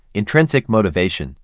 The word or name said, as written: Intrinsic motivation